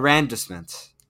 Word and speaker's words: arrondissement: Arrondissement. Like Arrondissement. – Yeah, there you go. – I think is how it's pronounced.